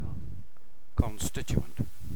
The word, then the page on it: constituent: British English